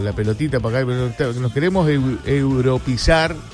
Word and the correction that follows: europeizar: ¿se dice así? – Sí, puede ser, sí. – Europeizar.